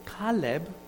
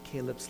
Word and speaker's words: Caleb: But Caleb, however, rhymes with Leb in Hebrew. And Leb in Hebrew means heart.